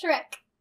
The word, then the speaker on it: Shrek: Shriek. That is right. The big green ogre. – No. That's Shrek. Oh, Shriek. – Did you spell Shrek? – No. – How'd you spell it? – S-H-I-E-K.